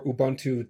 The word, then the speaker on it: Ubuntu: And he is the engineering manager for Ubuntu Desktop.